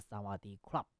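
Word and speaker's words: 萨 瓦 迪 club (สวัสดีครับ): 但其实呢，这个泰语哈、似乎有这个阴性阳性的这个区别，女生啊讲你好是萨瓦迪卡啊，但是男生呢啊，这个发音要是萨瓦迪 club 啊。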